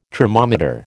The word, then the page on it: tromometer: American English